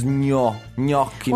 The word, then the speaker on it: gnocchi: But it only takes 1 second to know that GN and a vowel like O. Is gnocchi.